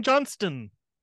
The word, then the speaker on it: Johnston: Got to pronounce that T. yeah. I know he hasn't been like. – It's a big distinction in my house, okay? – That's right. The Johnstons really versus personal. The Johnsons.